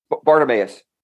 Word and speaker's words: Bartimaeus: This Bartimaeus, am I pronouncing it right? – Bartimaeus.